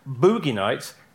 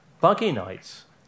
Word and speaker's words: Boogie Nights: But please, for the love of all things holy, stop referring to his earlier film as Boogie Nights, because it's pronounced Buggy Nights. Did you know that, David? – Buggy Nights. – Buggy Nights. – As in, like, a buggy.